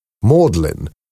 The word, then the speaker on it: Magdalen: Magdalen, pronounced Magdalen, has a very beautiful bell tower next to a medieval bridge.